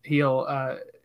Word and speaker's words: Gil: and then a 53rd minute Carlos Gil, Heel, you know, I guess you pronounce it with the H, right?